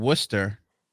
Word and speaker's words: Worcester: In Worcester, which we spell Worcester, which the English language sucks dick.